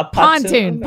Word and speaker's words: pontoon: Is it a patoopo? – Oh my gosh, I just said it. – A pontoon. Pontoon.